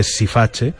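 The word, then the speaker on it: Siface: Él interpretaba eh, Sifache, el rey Sifax, en italiano se dice Sifache,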